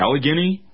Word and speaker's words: Allegheny: Allegheny